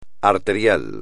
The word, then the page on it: arterial: espagnol